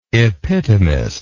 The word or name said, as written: epitomist